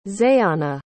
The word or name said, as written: zayonna